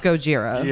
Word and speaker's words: Gojira: It's Gojira.